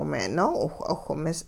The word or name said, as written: me'-noo'iwh'awh-mis